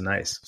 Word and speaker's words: gneiss: If you Google it, G-N-E-I-S-S, that's a rock too. Night. It's pronounced nice.